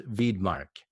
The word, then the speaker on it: Widmark: It's spelled with a W, mm-hmm. but I think it's pronounced Widmark.